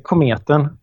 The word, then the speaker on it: Kometen: Uh, is that the, is that, again, is that the right pronunciation? Uh, it's, it's good enough, like Kometen. Oh, okay. I never would have guessed that. So it, it means comet.